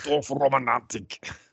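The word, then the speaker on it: Dorfromantik: Say it like Germans. – Dorf Romantik.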